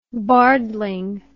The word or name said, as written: bardling